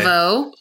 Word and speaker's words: Laveau: Laveau.